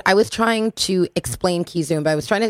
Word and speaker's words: Kizomba: Kizomba, K-I-Z-O-M-B-A.